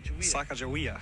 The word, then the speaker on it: Sacagawea: Sacagawea.